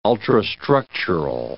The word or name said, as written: ultrastructural